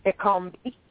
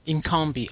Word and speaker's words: Ekombi: Did I pronounce that right? – Ekombi. – Ekombi, okay.